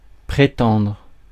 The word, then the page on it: prétendre: [pʁe.tɑ̃dʁ]; France